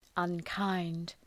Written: unkind

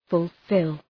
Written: fulfil

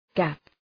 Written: gap

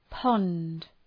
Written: pond

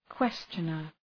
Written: questioner

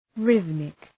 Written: rhythmic

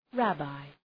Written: rabbi

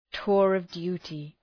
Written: tour of duty

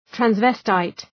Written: transvestite

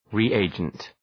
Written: reagent

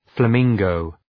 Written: flamingo

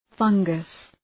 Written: fungus

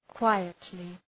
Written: quietly

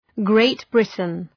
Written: Great Britain

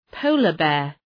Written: polar bear

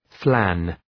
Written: flan

